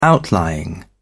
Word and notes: outlying: British English

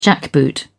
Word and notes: jackboot: British English

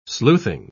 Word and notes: sleuthing: British English

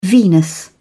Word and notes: venous: British English

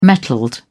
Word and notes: metalled: British English

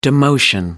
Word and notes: demotion: American English

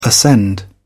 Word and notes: ascend: British English

